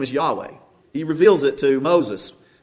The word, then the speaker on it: Yahweh: And the letters of that pronounced in Hebrew, Yahweh.